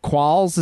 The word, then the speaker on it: Qualls: is when you start talking to him about his co-star DJ Qualls.